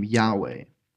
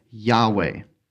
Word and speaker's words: Yahweh: And it's pronounced by some as Yahweh. But the Hebrew language didn't have vowels. So these letters, they fit together, and we fill it in with the ah and the way.